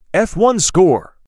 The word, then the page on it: F1-score: F1